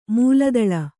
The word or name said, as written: ಮಲ್ಲದಾಳ